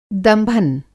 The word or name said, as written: दंभन